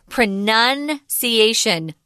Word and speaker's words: pronunciation: But unfortunately, like some English words, we make it harder and more tricky for you because we change the pronunciation when it turns into a different form of the word. This is the noun form, and it's pronunciation. The verb is pronounce. Pronounce, but pronunciation, be careful with that.